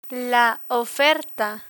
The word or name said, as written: oferta